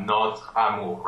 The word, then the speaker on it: notre amour: And, well, it's not really silent, technically, but the word really sounds like it finishes on TR, notre amour, right? – Exactly.